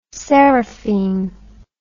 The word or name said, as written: seraphine